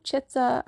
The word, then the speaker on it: Chitta: But also, it's called Chitter and it's spelt C-H-I-T-T-A because of Chitta Vritti Nirodha,